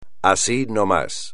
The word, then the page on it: así no más: espagnol